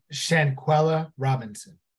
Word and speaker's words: Shanquella Robinson: Shanquella Robinson.